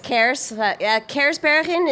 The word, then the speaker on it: Kersbergen: So Kersbergen. Yeah. It actually Kersbergen. Translates to Cherry Mountains. It's a Dutch name. – Cool. – Kersbergen. – Yes, Kersbergen is the proper pronunciation. So Kers is cherry, Bergen is mountains.